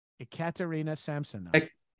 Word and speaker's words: Ekaterina Samsonova: Katarina with it's Katarina with an E before it, so just say Ekaterina Samsonova.